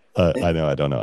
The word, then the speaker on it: I Know IDK: Like, how do you say it? – I know IDK. – Okay. – just answer, I know, I don't know. – I know, I don't know. I love it.